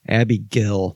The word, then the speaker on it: Abigail: Abigail. That's how you pronounce that. There's no AI. It's not Gail. It's Abigail. Like Abby and then G-E-L.